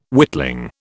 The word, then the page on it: witling: British English